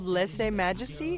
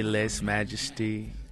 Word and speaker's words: Lese Majesty: The album is called Lese Majesty. Is that how you pronounce it? – You could say it that way. You could say Les Majesty, Les Majest.